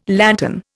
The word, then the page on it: lantern: Indian